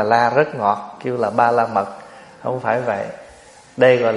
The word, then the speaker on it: Ba La Mật: Tiếng Phạn đọc là Ba Ra Mi, tiếng âm mình đọc là Ba La Mật.